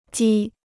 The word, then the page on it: 鸡: jī